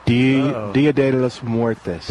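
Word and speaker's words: Día de los Muertos: Dia de los Muertos.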